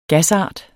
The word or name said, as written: gasart